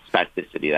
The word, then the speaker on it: spasticity: Okay, so there's something called post-stroke spasticity. Am I pronouncing that right? – Yes, spasticity. That's perfect.